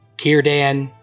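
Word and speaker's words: Círdan: Also, in the book, unlike this movie, C sounds in Elvish are hard C. So Cirdan, Celeborn, Cirith Ungol.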